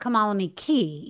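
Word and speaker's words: Kamalame Cay: Kamala me K. – Kamala me K. – But I think it's pronounced Kamala me key, even though it says K.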